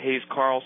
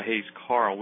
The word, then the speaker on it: Hayes Carll: But the important thing for everyone to know is that this new record, Trouble in Mind, by this fella Hayes Carl, which is spelled C-A-R-L-L. – It is, yeah, Hayes, H-A-Y-E-S-C-A-R-L-L.